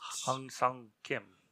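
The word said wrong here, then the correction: Ha-seong Kim: Ha Sung? I don't know how to say it. Kim. – Yeah, Ha Sung Kim.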